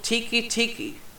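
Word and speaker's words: Teke Teke: This one's labeled Tiki Tiki. Not tiki as in the drink or freaking hut. It's spelled T-E-K-E.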